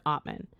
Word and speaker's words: Atman: So we have Atman, which is spelled A-T-M-A-N, but pronounced Atman.